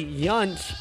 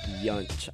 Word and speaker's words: Yount: His brother's Hall of Famer, Robin Yount, I believe is how you say that name, Yount.